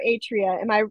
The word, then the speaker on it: Atria: So first, let me just make sure, Atria or Atria? Am I pronouncing that correctly? – Atria. I had to learn Atria. How to say it too.